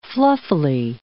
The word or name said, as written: fluffily